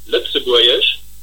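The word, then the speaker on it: Lëtzebuergesch: Also vor allem spricht man mal Lützeburgisch.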